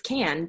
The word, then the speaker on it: Cann: I always say to him, Kevin Kane, and it's Kevin Can. So since I met him. – If I ever meet him, I'm going to butcher his last name now. I'm throwing you under the bus. – Hey, it's literally C-A-N-N. – It's Kevin Kane. – It's Can, but I say in my head, Kane.